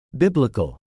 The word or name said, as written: biblical